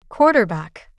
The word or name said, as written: quarterback